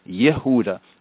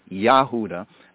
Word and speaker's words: Yehuda: Why is Judah in Hebrew properly pronounced Yehuda and not Yahuda,